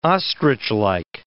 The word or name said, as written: ostrichlike